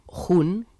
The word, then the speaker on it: Jun: La gente tiene tendencia a pronunciarlo como si fuera una J anglosajona, como un Jun, Jun. Uh-huh. Pero en realidad aquí, en España, tenemos una J maravillosa. Uh-huh. En español tiene la J del jamón y entonces es Jun.